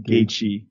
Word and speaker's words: Gaethje: Can you give me the real pronunciation there? – Justin Gaethje. – Gaethje. Gaethje's got it.